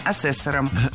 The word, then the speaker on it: ассессоров: Ассессоров – неверно. Правильно – ассессоров. Существительное «ассессор» имеет неподвижное ударение на втором слоге. Ну а раз оно неподвижное, значит, мы его не двигаем при изменении слова. Ассессоров – ассессорами – ассессором.